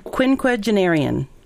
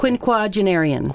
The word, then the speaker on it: quinquagenarian: Quinquagenarian. – Quin? What's that second syllable? – Quinquagenarian. – Quinquagenarian. Okay. All right. Q-U-I-N. Quinquagenarian. Q G-E-N-I... E-I-E-R-I.